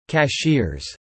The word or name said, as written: cashiers